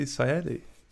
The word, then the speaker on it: Sayadi: Today we've got Tanzy Sayadi.